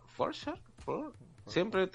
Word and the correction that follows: Farquaad: Es Farquaad, Lord Farquaad.